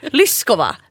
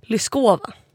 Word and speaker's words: Lyskova: Men alla säger Lyskova med betoning på alltså oet som ett å, men tänk Lyskova.